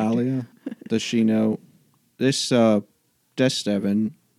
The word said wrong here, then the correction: Valstevin: is he... – Valstevin.